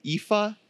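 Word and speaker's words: Aoife: It's, also, we haven't given a shout out to Efi McCardle. Did I say that right? Efa. – Yep.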